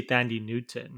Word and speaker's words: Thandiwe Newton: I don't know if I'm saying her name right. – Thandiwe Newton. I think you say Thandi Newton.